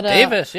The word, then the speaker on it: Davies: Don't you pronounce it Davis or Davies? – It is indeed Davis. But Davis, yeah.